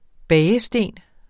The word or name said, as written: bagesten